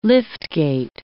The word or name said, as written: liftgate